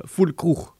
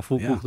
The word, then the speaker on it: Füllkrug: En dat bleek Morata en Füllkrug bleken dat te zijn. – Goed uitgesproken. – Zeg. Ja, dankjewel. Ja, Füllkrug. Ja.